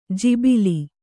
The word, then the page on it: ಜಿಬಿಲಿ: jibili